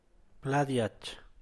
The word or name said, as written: plad diach